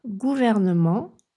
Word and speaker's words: gouvernement: En français, « gouvernement » compte plus de syllabes qu'en anglais « government ». « Gouvernement » 4 syllabes, « government » 3 syllabes.